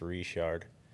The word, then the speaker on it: Richard: So it's actually pronounced Richard.